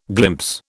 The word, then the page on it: glimpse: British English